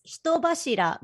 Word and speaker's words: Hitobashira: You're doing fine. It's Hitobashira, but like there's no need to pronounce it super Japanese.